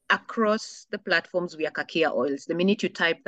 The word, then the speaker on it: Kakia: Kakia Oils. – Yes, Kakia Oils.